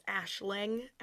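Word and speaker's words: Aisling: Yes, I looked up the pronunciation. It's Ashling. Ashling.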